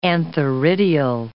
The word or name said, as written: antheridial